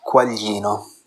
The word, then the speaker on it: Quaglino: I am so used to people calling me Quaglino. I grew up in New Jersey, so it's, you know, it's normal. But If you want to know the Italian pronunciation, it's Quaglino. The GLI is Yi in Italian. But, so, not hating you guys, or not hating you, Vanya.